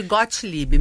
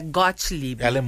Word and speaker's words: Gottlieb: Foi, mas vem de Gottlieb, meu sobrenome é Gottlieb. – É alemão, G-O-T-T, e esse G-O-T-T